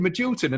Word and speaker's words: Magilton: And when I watched it back, I was like, no, that's Jim Magilton. And now I can Wikipedia and like, no, he was playing in that game. So yeah. My whole life, I've thought Jim Magilton was a different player.